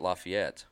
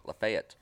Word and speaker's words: Lafayette: They don't pronounce it Lafayette. They pronounce it Lafayette, Tennessee.